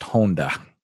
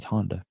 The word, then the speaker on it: Honda: Yeah, my last name in Japan is pronounced Honda. – Uh-huh. – And so... – Is that how the car is pronounced as well? – Yeah. – Okay. – Yeah, so... but we Americanize it for the sake of everyone. It's Honda.